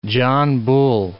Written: John Bull

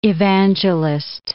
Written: evangelist

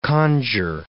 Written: conjure

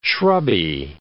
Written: shrubby

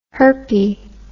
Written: herpie

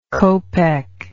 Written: copeck

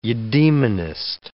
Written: eudaemonist

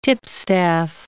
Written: tipstaff

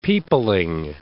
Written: peopling

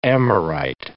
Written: Amorite

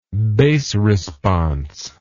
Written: bass response

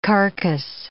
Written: carcass

